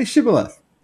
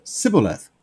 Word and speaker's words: Shibboleth: And they said to him, Say Shibboleth. And they said, Sibboleth, for they could not pronounce it right.